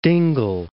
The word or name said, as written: Dingle Bay